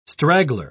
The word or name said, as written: straggler